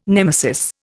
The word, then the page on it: nemesis: Indian